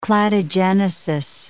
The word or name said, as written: cladogenesis